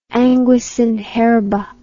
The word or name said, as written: anguis in herba